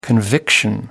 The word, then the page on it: conviction: American English